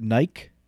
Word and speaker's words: Nike: Do you guys know that in the UK... they pronounce it Nike.